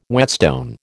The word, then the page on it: whetstone: American English